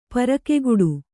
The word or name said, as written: ಪರಕೆಗಟ್ಟು